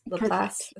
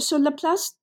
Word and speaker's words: Laplace: Laplace.